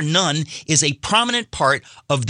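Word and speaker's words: nun (ن): The Arabic letter pronounced nun stands for Nazarene, a derogatory reference to Christians in large swaths of the Middle East.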